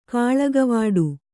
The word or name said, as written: ಕಾಳಗವಾಡು